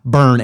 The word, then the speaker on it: Byrne: By the way, my last name is pronounced Burn, and he put that in there because I have a tough time a lot of times with people's last names, and it's spelled B Y R N E. And I wouldn't have known how to say that.